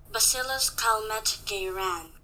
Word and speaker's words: Bacillus Calmette-Guérin: BCG stands for Bacillus Calmette-Guérin.